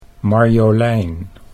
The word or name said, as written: Marjolein